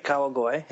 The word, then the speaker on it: Kawagoe: Kawagoe. Am I not pronouncing that right? Or it sounds right, Kawagoe. Yeah.